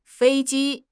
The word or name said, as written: fei1 ji1